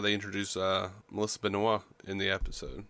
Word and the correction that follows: Benoist: Benoist, or however you say her name. – Yeah, it was Benoist.